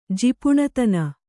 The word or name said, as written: ಜಿಪುಣತನ